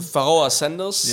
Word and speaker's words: Pharaoh Sanders: Pharaoh Sanders.